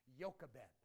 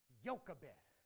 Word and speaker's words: Jochebed: And her name is, this is a J, but you pronounce it with a Y, Jochebed. Jochebed.